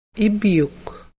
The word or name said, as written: ibyuk